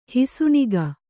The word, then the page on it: ಹುಸಿನಗೆ: husi nage; kannada